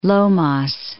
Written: Lomas

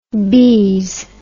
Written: bize

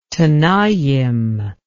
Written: tenaim

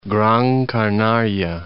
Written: Gran Canaria

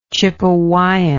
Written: chipewyan

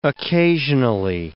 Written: occasionally

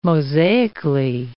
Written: mosaically

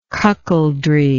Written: cuckoldry

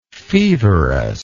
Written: feverously

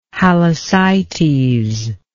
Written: halysites

